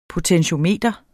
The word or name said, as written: potentiometer